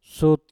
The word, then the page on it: sut: mpur